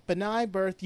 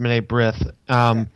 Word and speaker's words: B'nai B'rith: Is that how it's pronounced? I always thought it was B'nai B'rith or something like that. – That might be it. – Yeah, I believe it's actually pronounced B'nai B'rith.